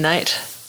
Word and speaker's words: Knight: Hastings spelled like the town in England where my dad was born, and Knight like Knights of the Round Table.